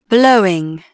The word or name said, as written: blowing